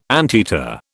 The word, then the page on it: anteater: British English